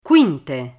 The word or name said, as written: quinte